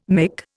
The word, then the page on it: mick: Indian